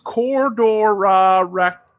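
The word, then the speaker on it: Kororareka: I gotta make sure I pronounce this right, because it's weird with the way the Aussies roll their R's and whatnot. But so it's Cordorareka was the town in New Zealand that they were occupying.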